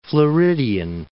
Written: Floridian

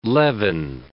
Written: leaven

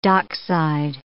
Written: dockside

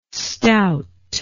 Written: stout

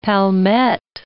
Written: palmette

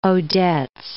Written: Odets

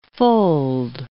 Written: fold

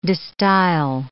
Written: de Stijl

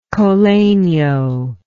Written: col legno

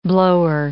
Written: blower

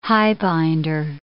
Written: highbinder

